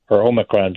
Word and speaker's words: Omicron: I've been corrected several times to say Omicron by my Greek colleagues. – So which is it, Omicron? Is that the proper pronunciation? – I think so, Omicron. – Omicron, all right.